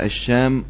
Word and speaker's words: الشام: ممكن تنطق الشام والشأم.